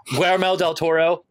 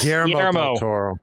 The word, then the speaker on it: Guillermo del Toro: During a discussion Guillermo del Toro, did I say it right? – Yes, Guillermo del Toro.